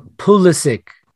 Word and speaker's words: Pulisic: That's why I said Pusik. – No, Pulisic. – Do I have to say it like that? Pusik. – No, Pulisic. It's with a U.